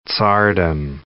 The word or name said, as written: tsardom